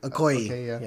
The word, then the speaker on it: Okoye: Danani Garia as Oakley. – Okoye.